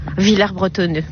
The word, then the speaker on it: Villers-Bretonneux: Je viens à Villers-Bretonneux depuis plus de 20 ans. – Vous savez qu'on prononce pas le S ici. Hein. – Villers-Bretonneux, pardon.